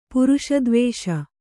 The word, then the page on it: ಪುರುಷದ್ವೇಷ: puruṣa dvēṣa